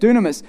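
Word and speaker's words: dunamis (δύναμις): The word in Greek is dunamis. That's how you say it, dunamis.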